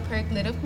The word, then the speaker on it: paralytic: I was listening to a podcast, and then she was talking about the paralytic man and how there was five friends, and the paralytical, am I pronouncing it right? – Paralytic.